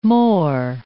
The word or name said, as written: mor